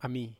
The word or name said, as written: ami